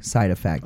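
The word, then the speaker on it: Psyde Affect: Side Effect.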